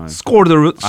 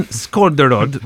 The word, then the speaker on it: Skårderud: Oh, the A with the little O, is it score? – I think so. – Skårderud. – I have been reading a lot about ABBA, so. – So you're tuned into the Nordic pronunciation. Finn Skårderud, right?